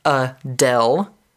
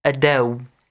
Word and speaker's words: Adele: The first one is Adele, in phonetics. The next one is Adele. With a W at the end.